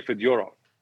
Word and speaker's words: Fedorov: So Russian names, for the most part, the accent is on the middle syllable, which is interesting because if he'd come over 20 years later, Sergei Fedorov would have been Sergei Fedorov. That's how you say his name in Russian.